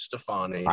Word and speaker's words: Stefani: No, it's not. It's Stefani. – I think everyone else has been saying it wrong this whole time. – I think it's a really long A.